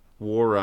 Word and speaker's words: Nwora: Wara. That's what we're going with. It's spelled N-W-O-R-A. Wara, I believe, is how it's said.